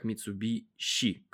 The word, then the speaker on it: Mitsubishi: Традиционное название фамилии автомбренда Mitsubishi чаще произносится как Mitsubishi, как суши, а не суси, или роще вместо России.